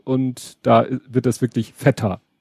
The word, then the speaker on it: Feta: so auf Griechisch basiert, weil mir halt eine Griechin mal gesagt hat, dass dieser Schafskäse Feta ausgesprochen wird. – Mhm. – Und erstmal die gute Nachricht, das habe ich kontrolliert, das kann ich beweisen, das, dem ist auch so.